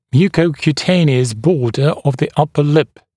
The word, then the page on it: mucocutaneous border of the upper lip: ˌmjuːkəukju’teɪnɪəs ‘bɔːdə əv ðə ‘ʌpə lɪp; ˌмйу:коукйу’тэйниэс ‘бо:дэ ов зэ ‘апэ лип